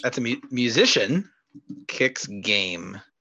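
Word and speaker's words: Qix: It kind of feels like really what this game kind of wants to be is, if you're familiar with a game that I think is pronounced Kix.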